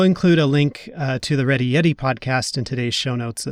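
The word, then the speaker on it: Reddy Yeti: you could check out the Ready Yeti podcast. It's not spelled quite how you might think it is spelled. It's R-E-D-D-Y, and then Yeti is Y-E-T-I.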